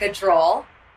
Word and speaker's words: Gujral: Today I'm interviewing Parul Gujral.